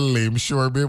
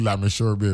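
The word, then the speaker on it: Lameshur Bay: Lamisho is spelled Lame Shore, L-A-M-E-S-H-U-R, Bay, right? It's spelled Lame Shore Bay, but it's really Lamisho Bay, right?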